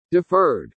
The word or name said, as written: DEFERRED